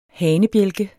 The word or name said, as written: hanebjælke